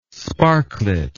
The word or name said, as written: sparklet